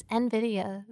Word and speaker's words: NVIDIA: I said NVIDIA on NVIDIA. TV once, and I got like 3 people pinged me, and they were like, it's NVIDIA.